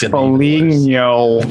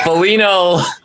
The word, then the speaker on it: Foligno: It's Foligno. – But don't say Foligno. Foligno.